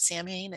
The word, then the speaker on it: Samhain: And the Celtic New Year actually begins on what we Westerners call Halloween, but it's also called Samhain, even though it's spelled S-A-M-H-A-I-N. It's not Samhain, it's called Samhain.